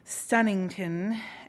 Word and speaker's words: Stonington: The geographical epicenters were the Cove and Stonington, pronounced in his Yankee way, Stunnington, and the exotic Bohemian Jungle House at 8128 Midnight Pass Road in Siesta Key.